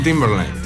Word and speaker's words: Timberlake: ¿Cómo se pronuncia ese apellido? – Justin Timberlake. – Timberlake.